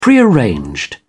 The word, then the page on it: prearranged: British English